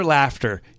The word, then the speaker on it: Laughter: His name is Peter Laughter. Used to be Lauder. He changed it to Laughter. Same spelling, just changed the pronunciation.